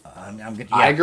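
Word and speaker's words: Ægir: Iger or Igor? – Iger. A-E-G-I-R. And it says to pronounce E-I-E, which is I-G-I-R. Iger.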